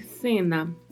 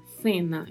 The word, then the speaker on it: cena: Então falaria-se cena, cena. Então, há as duas possibilidades de pronúncia: cena ou cena. Na maioria das vezes, na América Latina, o som é o som de S mesmo, cena e na Espanha é o som gerado com a língua entre os dentes, Cena.